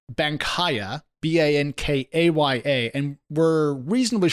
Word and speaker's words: Bankaya: Yeah, well, according to CEO and co founder Mauricio Cordero, it's pronounced more Bankaya, but he breaks it down a little bit more by saying Banca Ya means bank now.